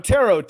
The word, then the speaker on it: tarot: T-A-R. No, tarot, T-A-R-O-T. The T, ooh, is silent.